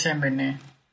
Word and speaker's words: Sembene: ¿Cómo se pronuncia? ¿Sembene o Sembene? – Creo que es Sembene.